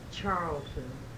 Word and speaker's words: Charlton: My name is Nellie Weber Charlton. You want the last name spelled? – Yes, please. – C-H-A-R-L-T-O-N.